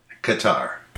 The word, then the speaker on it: catarrh: Is it Qatar or? – Qatar. – Qatar. – Rhymes with Johnny Qatar.